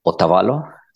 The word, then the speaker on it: Otavalo: Otavalo. So it's, let's see, O-T-A. Uh-huh. V-A-L-O. – Okay, exactly like I did it.